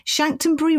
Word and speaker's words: Chanctonbury: And forgive me if I mispronounce it. Chanctonbury Ring, is that correct? – That's right, yeah.